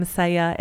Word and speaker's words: Seja: It's like my name, Seja, S-E-J-A, not like the song Say, Say, Say by Paul McCartney and Michael Jackson.